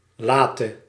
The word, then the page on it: tard: France; /taʁ/